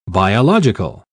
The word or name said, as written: biological